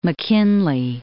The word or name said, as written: McKinley, Mount